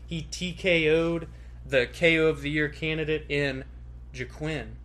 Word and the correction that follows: Joaquin: Is that how you say it, Buckley? – Joaquin. – Damn it. I always mess up his first name.